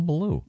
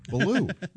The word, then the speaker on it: Ballou: But Boogaloo has nothing to do with Ballou. – No. – It's Cat Ballou. – No, that doesn't have anything to do with Boogaloo. – It rhymes with Boogaloo.